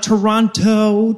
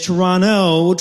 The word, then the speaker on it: Toronto: I have it on good authority that it's not Toronto to rhyme with pronto. It is Toronto to rhyme with guano.